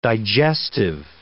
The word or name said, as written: digestive